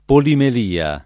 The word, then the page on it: polimelia: lingua italiana